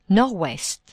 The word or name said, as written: nord-ouest